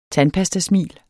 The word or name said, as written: tandpastasmil